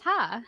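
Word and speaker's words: ha (ห้า): It's pronounced ha.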